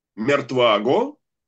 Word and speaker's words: Мертваго: Мертваго, с ударением на предпоследний слог, и две с ударением на последний слог.